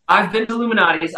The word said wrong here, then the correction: Lou Malnati: I will say, can you pronounce it for me? Because I think I might have butchered it just now. – Lou Malnati? – Lou Malnati's? – Lou as in Lewis. Lou Malnati. – Lou Malnati, okay.